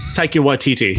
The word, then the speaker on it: Taika Waititi: I mean, hell, like you said, you got, how do you pronounce his name? Taika Waititi? – Taika Waititi, yeah.